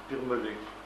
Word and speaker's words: Birmelet: Comment ? Birmelet. C'est comme ça se prononce.